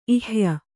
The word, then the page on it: ಇಹ್ಯ: kannada